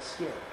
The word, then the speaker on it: SCIP: Well, the folks at the Rural Development call it SCIP, because I would agree with you. I don't think the C should sound like a K, but that's how they say it.